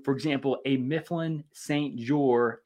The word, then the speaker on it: Jeor: We use the Mifflin St. Jour. It's spelled J-E-O-R.